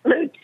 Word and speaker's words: Lutz: Lutz. That's the way we pronounce it down here, anyway.